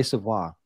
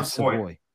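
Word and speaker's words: Savoie: It's Matt Savoy, right? I always want to say Savoy. – No, Savoy. – Okay. Can you tell us a little bit about this player? – Yeah, so Matt Savoy is a center, but he also plays the wing.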